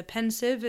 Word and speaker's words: Pensieve: Sorry, can I note the correct pronunciation of Pensieve, not Pensieve, as it is in the bloody movies.